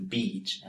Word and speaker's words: beach: The sounds we have here, according to the International Phonetic Alphabet, are E for beach and I for bitch. So as you can see and hear, there really is a difference when pronouncing beach and bitch.